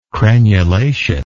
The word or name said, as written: crenulation